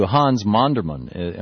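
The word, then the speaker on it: Monderman: You credit a lot of the concepts that you have to Hans Mondermann. Am I pronouncing that right? – Yes.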